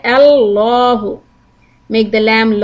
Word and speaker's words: Allahu: Allahu. We can pronounce the lam in Allahu either light or heavy.